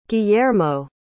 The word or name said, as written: gillermo